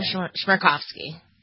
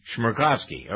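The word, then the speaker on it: Chmerkovskiy: Maxim Shmerkovsky. – Shmerkovsky.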